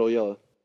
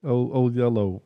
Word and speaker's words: Oyelowo: It's Oyeller. – David Oyeller. – Oh, no, yeah. David Oyelowo. Oyelowo. Okay.